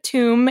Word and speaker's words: tomb: So the word tomb in English comes from the French word tombe, where they pronounce the B a little bit harder. Mm-hmm. And I think the B became silent in the Middle English period.